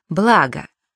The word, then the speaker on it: благо: К ним относятся, например, такие слова. Благо. Благом, благами, о благах. Петля. Петлю, петлей, о петле. Договор. Договора, о договоре.